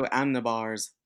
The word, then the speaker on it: Amenábar: It is Alejandro Amenábar. Oh God. I'm Hispanic. I should say that right. Alejandro Amenábar's The Others.